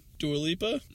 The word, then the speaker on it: Dua Lipa: I think it's how you pronounce her name? Dua Lipa? – Sounds right, yeah.